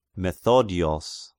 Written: Methodios

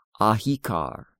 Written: Ahikar

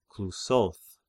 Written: Clusoth

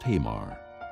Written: Tamar

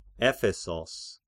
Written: Ephesos

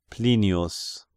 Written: Plinius

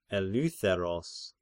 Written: Eleutheros

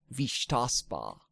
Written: Vishtaspa

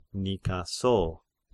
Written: Nikaso

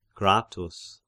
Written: Gratus

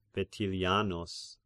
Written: Vetilianus

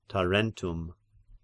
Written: Tarentum

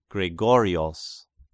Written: Gregorios

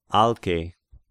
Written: Alke

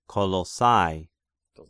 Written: Colossai

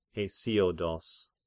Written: Hesiodos

